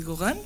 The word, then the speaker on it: Gauguin: Porque también vemos una discusión entre Van Gogh y Gauguin, ¿se pronuncia? – Sí.